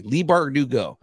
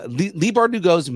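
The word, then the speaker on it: Leigh Bardugo: Lee Bardugo. Lee Bardugo's massive work on the Grishaverse will be reflected in the new season even more heavily.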